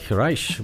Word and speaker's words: Reich: Da, Steve Reich, cum își pronunță el numele, Reich, mă rog, vine în Toronto.